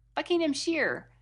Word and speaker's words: Buckinghamshire: So the emphasis is on the first syllable, Buckinghamshire. Buckinghamshire.